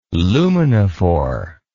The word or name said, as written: luminophore